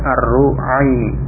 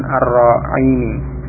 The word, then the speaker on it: الرعيني: Jadi Ar-Ru'aini dibaca dengan ro-nya dengan dhammah, berarti membacanya Ar-Ru'aini. Ru'ain, bentuk tasghir. Ar-Ru'ain. Ada sebagian yang membaca dengan fathah Ar-Ru'aini, tapi yang ditunjukkan dalam tulisan itu ro-nya di dhammah, menunjukkan dibaca Ar-Ru'aini, bukan Ar-Ru'aini. Ini.